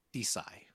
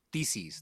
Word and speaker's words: theses: I fucking search through Google Scholar just to read theses, thesi, wait, theses, because it rhymes with feces, like multiple theses just to see random ideas about shit.